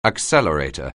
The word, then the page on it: accelerator: anglais